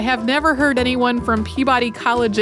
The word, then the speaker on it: Peabody: And as for the other one, listeners may also notice that Holly says Peabody in this episode, while the interviewees say Peabody. George Peabody, who's the museum's namesake, pronounced it Peabody, although there are a lot of institutions named for him that use that pronunciation and others that don't.